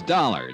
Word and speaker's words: Dollar: It's Dollar, Johnny Dollar. – Dollar, huh? – Yeah, rhymes with color.